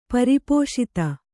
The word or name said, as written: ಪರಿಪೋಷಿತ